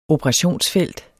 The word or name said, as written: operationsfelt